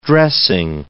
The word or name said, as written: dressing